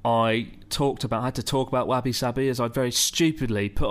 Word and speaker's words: wabi-sabi: Wabi sabi, that's it. There Wabi we go. Wabi sabi. – It rhymes.